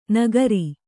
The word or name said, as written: ನಗರಿ